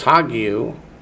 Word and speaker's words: hagiou: That in Greek is pneumatos hagiou, which is Holy Spirit, holy there being an adjective and spirit being a noun.